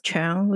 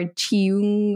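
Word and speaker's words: Cheung: But the problem with her last name is that a lot of people don't know how to say it because it's C H E U N G. Like it's supposed to be pronounced Chung, but then people are like Chung or Chiung or I don't know.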